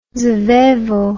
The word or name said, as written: svevo